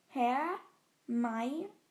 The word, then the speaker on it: Hermione: Im vierten Band erklärt Rowling die richtige Aussprache, indem sie Hermine ihren Namen langsam Viktor Krumm vorsprechen lässt. Hermione.